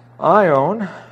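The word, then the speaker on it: aion: And then we also have ion, and this is a long O, A-I-O-N, telos, T-E-L-O-S.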